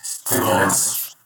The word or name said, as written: Stimulants